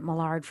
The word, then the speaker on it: Millard: I always pronounced it Millard for years, but then it's Millard, obviously, but lead singer of Mercy Me.